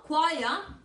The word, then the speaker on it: choir: Choir must always rhyme with wire. Coro, choir, debe siempre rimar con wire, alambre.